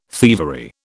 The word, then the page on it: thievery: British English